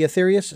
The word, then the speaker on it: Aetherius: I want to ask you about the, obviously, about the society, which, again, I was so intrigued by, the Aetherius, if I'm pronouncing that correctly, the Aetherius Society. – Yeah, that's correct.